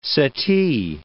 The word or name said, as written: settee